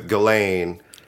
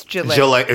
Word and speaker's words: Ghislaine: You see, they got Ghislaine Maxwell. She's going away for 20 years. Like, what makes that situation, and I know upsets you too, with Ghislaine. – Ghislaine. It's Ghislaine.